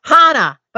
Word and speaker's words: Chana: And you've got this cool book because folks, her name is pronounced Hannah but it's spelled C H A N A.